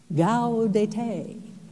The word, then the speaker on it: Gaudete: Not Gaudete. Well, how do you say it? Gaudete, she said. Gaudete.